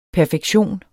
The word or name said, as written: perfektion